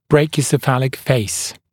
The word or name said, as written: brachiocephalic face